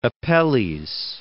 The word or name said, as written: Apelles